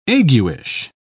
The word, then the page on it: aguish: British English